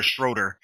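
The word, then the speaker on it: Schröder: I can never, I always had a hard time pronouncing the name, Schrader or Schroeder. Schroeder, thank you.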